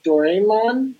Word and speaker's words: Doraemon: It's a uh, uh, uh, the the anime or whatever is called Doraemon. I mm think is, is the, is how you pronounce it and they're promoting uh, the new movie Doraemon Nobita and the Birth of Japan 2016.